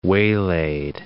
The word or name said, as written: waylaid